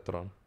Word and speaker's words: Theron: Se pronuncia Tron. – ¿Se pronuncia Tron? Sí, güey. ¿Como la película? – No, no Tron, pero Tron.